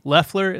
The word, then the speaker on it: Loeffler: Let's talk about Kelly Loeffler.